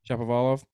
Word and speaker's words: Shapovalov: Even though he's still a great player, same thing with Shapovalov. I don't really know how to pronounce it correctly. – Shapovalov.